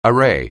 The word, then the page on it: array: anglais